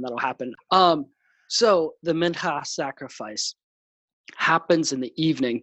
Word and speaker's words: mincha: It the Hebrew word behind it, and forgive me on my Hebrew pronunciation, but the mincha. That's my. You like how I really that guttural H there?